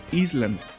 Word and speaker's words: island: En inglés se escribe Island, pero ¿sabías que se pronuncia island? Es decir, la S es muda.